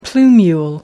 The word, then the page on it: plumule: American English